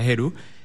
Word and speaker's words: Heru: Y como tú dices, creamos la plataforma de tecnología de impuestos Heru, se escribe con H, pero se pronuncia Heru,